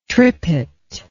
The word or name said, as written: trippet